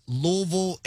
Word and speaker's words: Louisville: It's Louisville, and I'm so happy and so thankful that y'all are saying it correctly.